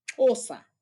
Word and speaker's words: Xhosa: And like, for instance, when I learned how to sing in Xhosa, actually it's pronounced Xhosa. It's like there's a click sound to it.